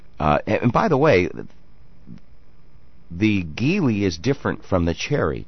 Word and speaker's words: Geely: Geely.